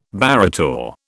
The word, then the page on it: barrator: British English